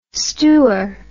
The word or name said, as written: stoor